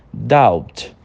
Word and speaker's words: doubt: Primeiro nós temos doubt. Doubt. Você repara que o B, ele, a gente considera ele mudo, mas ele tem uma entonação bem levinha lá no fundo, sabe?